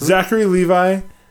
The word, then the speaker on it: Zachary Levi: Zachary Levi, is that how you say his name? – Yep.